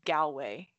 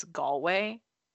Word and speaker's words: Galway: So yeah, a lot of Americans say Galway for the city, but it's Galway. Oh my god, and that is such a huge, like, I didn't do any research at all.